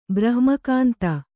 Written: ಬ್ರಹ್ಮಕಾಂತ

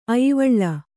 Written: ಆಯ್ವಳಿ

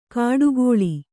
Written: ಕಾಡುಕೋಳಿ

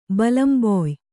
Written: ಬಲಂಬೊಯ್

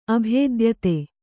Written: ಅಭೇದ್ಯತೆ

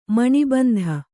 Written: ಮಾಣಿಬಂಧ

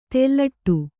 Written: ತೆಲ್ಲಟಿ

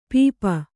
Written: ಪೆಪ್ಪು

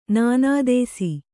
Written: ನಿನದಿಸು

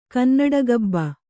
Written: ಕನ್ನಡಗಬ್ಬ

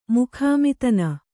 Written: ಮುಖಾಮಿತನ

